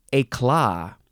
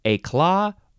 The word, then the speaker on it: éclat: This word is éclat. Yeah, you can emphasize either syllable, éclat or éclat.